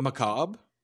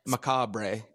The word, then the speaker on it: macabre: Wait till you see how it's spelled. It ends with R-E. – Yeah. Yeah. Yeah. – Really? – Macabre.